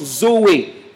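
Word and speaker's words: Zoé (ζωή): Le mot vie ici, V-I-E, c'est un mot grec derrière que l'on prononce Zoé, c'est-à-dire la vie éternelle, la vie de Dieu.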